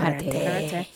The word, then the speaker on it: karate: And last but not least is karate or karate, right? Is that how you pronounce it? – Karate.